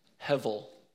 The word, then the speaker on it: hevel: You see, the word in Hebrew is pronounced hevel. I'm going to be like Pastor Jason real quick. Everybody say it with me. Hevel. Hevel. H-E-V-E-L.